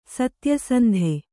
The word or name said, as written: ಸತ್ಯಸಂಧ